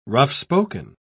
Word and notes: rough-spoken: British English

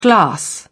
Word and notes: glass: British English

